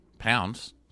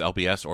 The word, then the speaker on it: LBS: It's spelled LBS, Pounds. So I guess it's LBS or Pounds, however you want to say it.